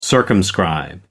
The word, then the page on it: circumscribe: American English